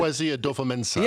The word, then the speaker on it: Kwesi Adofo-Mensah: I think that, Kwesi Adofo-Mensah.